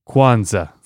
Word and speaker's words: Kwanzaa: Creo que se pronuncian Kwanzaa.